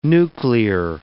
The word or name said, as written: nuclear